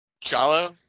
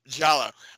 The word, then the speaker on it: Giallo: Giallo? Did I say it right? – Yep. – Holy fuck. I finally did it. Giallo.